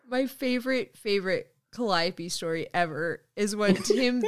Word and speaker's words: Calliope: and she said Calliope, it's like cantaloupe, like word for word what I said, it's like cantaloupe, so it's Calliope.